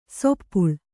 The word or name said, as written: ಸಪ್ಪಳು